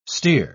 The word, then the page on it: stere: British English